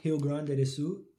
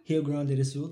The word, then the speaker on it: Rio Grande do Sul: And then we actually took a trip here to the south, Rio Grande do Sul. Did I say it right? – Yeah. – Rio Grande do Sul, to where Marcelli is from.